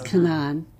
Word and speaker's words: Canaan: And they use the Palestinian pronunciation, which is Canaan.